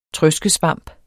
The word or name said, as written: trøskesvamp